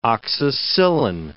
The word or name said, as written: oxacillin